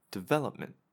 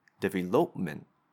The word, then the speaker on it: development: If we incorrectly use pitch and choose or highlight the wrong syllable, development, then the word changes and it doesn't sound accurate. So it's very important to get a good grasp of the pitch. You don't have to say it louder, right? Development. They're both the same relative loudness, but one syllable is higher than the rest, and it changes the overall pronunciation and delivery of the sound, of the word.